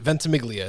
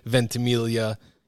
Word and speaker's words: Ventimiglia: Ventimiglia. It's it's not the Italian yeah. pronunciation, which would be Ventimiglia.